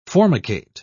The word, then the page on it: formicate: British English